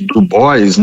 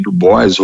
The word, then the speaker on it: Du Bois: Du Bois, né? Algumas pessoas falam W.E.B. Du Bois, ou falam com a pronúncia mais afrancesada, que é o Dubois, William Edward Burrell Dubois, ou Du Bois, que era a NAACP, National Association of Advanced of Colored People.